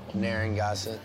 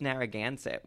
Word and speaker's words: Narragansett: I'll have a Narragansett. – The Gansett? – Yeah, please. – And how do you say it? – It's Narragansett, which is a place in Rhode Island.